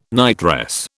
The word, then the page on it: nightdress: British English